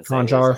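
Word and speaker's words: Konchar: Well, I was really impressed tonight with his ability to switch on defense because, you know, he would go from Gordon to Conchon or I can't even Conchar.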